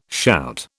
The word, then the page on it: shout: British English